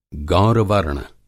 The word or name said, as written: गौरवर्ण